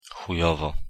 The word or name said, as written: chujowo